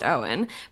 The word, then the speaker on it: Eoin: No, actually, but I knew, not personally, I knew of an actor whose first name was spelled the same way, E-O-I-N, and that was pronounced Owen, but I did not check, because I did a bad job, on this person's name.